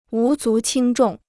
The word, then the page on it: 无足轻重: wú zú qīng zhòng